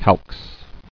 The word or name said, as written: Calxes